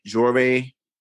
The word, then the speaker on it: Joire: Is it Jory? – Yeah, you said it right. Jory's, so my mom Marjorie. So it's Jory's. Jory for Marjorie.